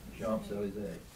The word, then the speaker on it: Champs-Élysées: Champs-Élysées. Anybody speak French? – Champs-Élysées.